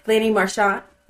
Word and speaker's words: Lanny Marchant: And then also your good friend, is it Lanny Merchant? Is that how you say it? – Lanny Marchant.